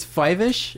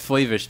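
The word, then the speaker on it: Fyvush: And it's Five-ish. Is that how you say it? – That's how they always said it. He got famous from. – I've always said Flavish because I used to watch Picket Fences. – From Picket Fences, yeah. I used to see interviews with him because that was like a breakout role for him. And I think that's how it's pronounced.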